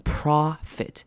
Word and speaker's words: profit: 好，profit 这个字也要注意的是 o 是短母音 o 的念法，而不是长母音 o，所以我们念成 profit。